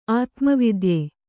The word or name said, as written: ಆತ್ಮವಿದ್ಯೆ